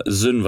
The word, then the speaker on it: Syn: Ja, gut, Aussprache Samara Sünn wahrscheinlich, weil S-Y-N ist die Aussprache.